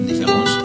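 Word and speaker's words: Indifférence: Y se llama Indifference, o bueno, Indifference, si lo pronunciamos bien, de Tony Morena.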